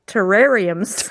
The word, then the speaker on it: terrariums: The next one, terraniums. What did I what? say that right? No terrariums. Terrariums.